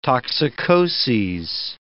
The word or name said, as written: toxicoses